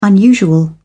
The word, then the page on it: unusual: British English